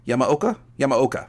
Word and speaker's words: Yamaoka: That was the dog ending theme from Silent Hill 2, and that was by Akira Yamaoka. Am I saying that right? Yamaoka.